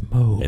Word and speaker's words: Moog: It's pronounced Mog. – Mog. – It's Moog. – And then there's Mog. – Dang it, it's Moog. Moog.